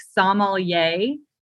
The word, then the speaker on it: sommelier: So the emphasis, it's supposed to be like sommelier. So like if I break it down that way, it's like yay at the end. So it's like sommelier, not like sommelier, which is like, sounds like you're from a country or something else.